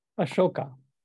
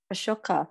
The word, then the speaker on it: Ashoka: Ashoka. – Ashoka.